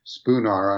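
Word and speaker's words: Szpunar: Yes, the advocate general, or one of the advocate generals of the European Court of Justice, Marek Spoonar, I'm hoping I'm pronouncing his name correctly. – That's a perfectly legitimate pronunciation.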